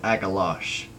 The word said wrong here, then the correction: Agalloch: But I believe it now that it is Agalloch.